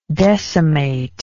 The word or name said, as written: decimator